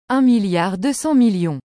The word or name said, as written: 1200000000